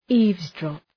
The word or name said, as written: eavesdrop